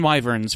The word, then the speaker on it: wyvern: A wyvern. W-Y-V-E-R-N.